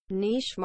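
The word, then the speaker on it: niche: نیچ مارکتینگ که تلفظ صحیحش اینطوریه نیش مارکتینگ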